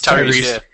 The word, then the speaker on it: Tyrese: I'm super excited that Tyrese... I'm saying that right? Yes. – Tyrese.